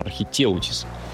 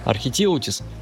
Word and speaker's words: архитеутис: В 19 веке наука официально признала, кракен существует, только зовут его гигантский кальмар или архитеутис, там ударение на «е», по-моему, архитеутис, вот, а также его еще более жуткий собрат, колоссальный кальмар.